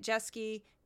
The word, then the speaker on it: Jeske: Those are both by my name, Juliet Jeske.